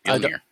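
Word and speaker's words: Mjölnir: Yeah, I don't think that's how you say it. – It is. Mjolnir. – I think it's like Mew Mew or something. – 100%. It's Mjolnir.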